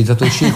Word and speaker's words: Zatoichi: Tu lo pronunci Zatoichi con l'accento finale. Quindi probabilmente ho sbagliato io.